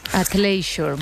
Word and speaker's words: athleisure: Pues el término acuñado para esta tendencia o esta manera de vestir es athleisure. – Athleisure, vale.